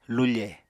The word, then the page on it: lufe: Apatani